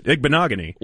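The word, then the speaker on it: Igbinoghene: He couldn't even get it out the first two tries, and then he just nailed it. Igbinogany. – Well, what you had was a producer. – He had a producer in his ear pronouncing it properly.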